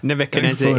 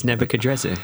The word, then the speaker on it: Nebuchadrezzar: Nebuchadnezzar is Nebuchadrezzar. – Yeah. Nebuchadrezzar is an older pronunciation.